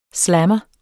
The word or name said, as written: slammer